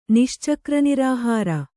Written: ನಿಶ್ಚಕ್ರನಿರಾಹಾರ

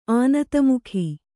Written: ಆನತಮುಖಿ

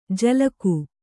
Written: ಜಲಗು

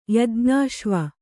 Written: ಯಜ್ಞಾಶ್ವ

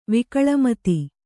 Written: ವಿಕಳಮತಿ

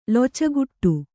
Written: ಲೊಚಗುಟ್ಟು

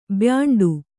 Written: ಬ್ಯಾಂಡು

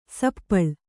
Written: ಸಪ್ಪಳು

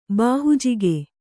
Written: ಬಾಹುಜಿಗೆ

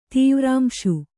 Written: ತೀವ್ರಾಂಶು

